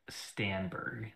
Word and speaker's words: Stenberg: Amanda Stenler? Is that how you say her name? Amanda Yes, yeah, Amanda Stenberg.